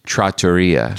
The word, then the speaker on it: trattoria: it's not pronounced trattoria, it's trattoria.